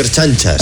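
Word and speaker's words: ertzainas: Por otro lado, que como usted dice, están formados por erchañas y etarras. Es una especie, a mí me recuerda bastante, erchanchas, se dice erchanchas.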